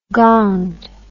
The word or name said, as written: gonged